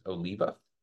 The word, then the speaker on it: Oliva: So the correct pronunciation is Oliva.